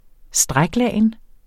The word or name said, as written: stræklagen